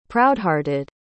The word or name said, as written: proudhearted